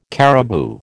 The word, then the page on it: caribou: American English